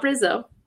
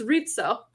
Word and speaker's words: Rizzo: It's not Rizzo, but I digress. Like, it's Rizzo.